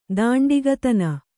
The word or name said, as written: ದಾಂಡಿಗತನ